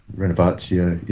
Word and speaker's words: Renovatio: I've been playing, I'm not sure how to pronounce it, Europe Renovatio, or Renovatio. – Renovatio, yeah.